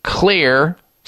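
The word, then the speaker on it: Xlear: and other retailers that carry Clear, spelled with an X, X-L-E-A-R, Clear Nasal Spray products.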